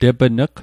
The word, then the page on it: tepn'g: Mi'gmaq